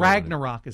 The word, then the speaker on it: Ragnarok: Ragnarok is how I pronounce it.